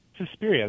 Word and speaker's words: Suspiria: Suspiria, that's right.